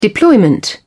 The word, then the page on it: deployment: British English